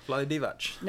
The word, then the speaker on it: Vlade Divac: Yeah, Vlade Divac.